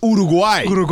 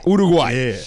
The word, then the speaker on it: Uruguay: You said it right. I anglicized it. It's Uruguay. – Uruguay.